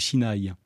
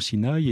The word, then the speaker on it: Sinaï: Et de fait, la prononciation Sinaï est une prononciation qui est issue de l'hébreu. Les Arabes disent Sina. Et pour nous, Français, nous recommandons donc vivement de dire Sinaï, qui est une prononciation typiquement française et qui évite de prendre parti entre les deux origines linguistiques.